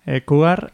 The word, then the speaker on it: Cougar: Cougar, lo he dicho bien. – Cougar, ya deja claras cuáles son sus intenciones.